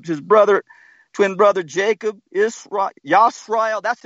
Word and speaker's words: Yisrael: He changed his name to Yisrael. Not Israel.